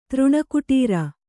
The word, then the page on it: ತೃಣಕುಟೀರ: kannada